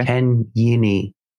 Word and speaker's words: Kanyini: Kanyini is spelt exactly like it sounds, with a K, Kanyini.